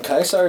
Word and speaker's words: Caesar: Everybody else called them Caesar's Legion. – Kaiser is actually how it should have. It, it is how the Romans would have pronounced it.